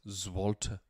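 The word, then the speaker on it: złoty: Zwolter. It's actually pronounced Zwolter.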